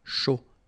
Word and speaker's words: chaud: All right, so, un cappuccino, not really difficult to produce, un chocolat chaud, remember, C-H, chaud, chocolat, and then final T not pronounced, chaud, final D not pronounced, un café glacé, un thé.